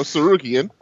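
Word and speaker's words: Tsarukyan: The T is silent, so Sarukian.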